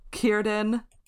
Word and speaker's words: Círdan: Círdan. – I'm so proud of you for that scene. – I practiced the hard C.